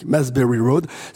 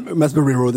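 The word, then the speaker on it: Mapesbury Road: Ça s'écrit M-A-P-E, comme une map, S-B-U-R-Y, Masbury Road. – 22, donc. – 22, Masbury Road, absolument.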